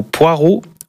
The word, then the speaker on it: poireau: Est-ce que vous dites plutôt poireau ou poro? – D'ivoire. – Comment vous prononcez le nom de ce légume? Un poro ou un poireau? – Poireau.